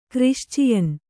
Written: ಕ್ರಿಶ್ಚಿಯನ್

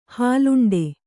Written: ಹಾಲುಂಡೆ

